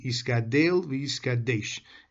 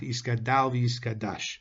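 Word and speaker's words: Yiskadel v'yiskadesh (יִתְגַּדֵּל וְיִתְקַדֵּשׁ): According to the Mishnah Berurah, the proper pronunciation of Kaddish is with a tsere under the Dalet, i.e. Yiskadel v'yiskadesh, and not with a patach Yiskadal v'yiskadash.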